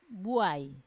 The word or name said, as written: buai